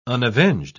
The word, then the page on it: unavenged: British English